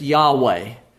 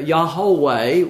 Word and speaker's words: Yahweh: YHWH. And we pronounce that, if we read that in a book, we would say it's Yahweh. But we put vowel points in there. Yahweh would be the way that it might be pronounced, which is how we get our word Jehovah.